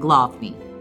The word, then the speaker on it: Główny: and its city center is Rynek Główny. The W's are always pronounced V's, so we'll try to do the best we can to keep that correct.